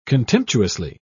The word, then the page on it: contemptuously: British English